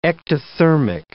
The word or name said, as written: ectothermic